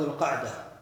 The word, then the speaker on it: القعدة: قال وذو القعدة بالفتح والكسر، بالقعدة وذو القعدة والفتح، يقولون بأن الفتح أشهر لغة لا أشهر نطقا، الناس ينطقونها القعدة بأكثر، وهي لغة فصيحة، لكنها يقول عند العرب أشهر، لكن عندنا كسر أشهر، ليست خطأ، سهلة.